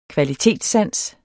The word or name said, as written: kvalitetssans